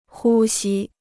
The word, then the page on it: 呼吸: hū xī